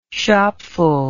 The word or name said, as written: shopful